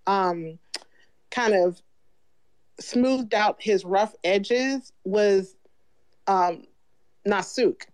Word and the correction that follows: Naksu: you know, was, why am I saying her name wrong? Naksu.